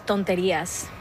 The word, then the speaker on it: Tonterías: And brand new, a song on that, am I gonna say it right? Tonterías. – Tonterías, perfect.